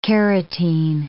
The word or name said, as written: carotene